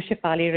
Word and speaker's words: Shefali: You know, my name is probably like one of the few Shefali Reynas in the world, so it's rather easy to Google me.